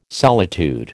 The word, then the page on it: solitude: American English